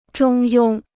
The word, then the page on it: 中庸: zhōng yōng